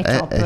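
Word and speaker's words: Čop: Confermi Chop. – Sì, è scritto Cop come poliziotto. – Sì. – Ma si legge Chop come centravanti.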